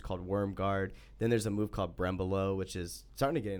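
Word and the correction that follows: berimbolo: Birimbolo, yeah. Yeah. Did I say it wrong? – Birimbolo. – Let me Americanize it. Brembolo.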